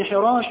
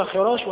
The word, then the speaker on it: حراش: حراش، والبعض ينطقها خراش وهذا خطأ، وقع لأخي ربعي بن حراش مثل هذه القصة التي وقعت لزيد بن خارجة وأنه تكلم بعد الموت.